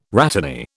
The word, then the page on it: rhatany: British English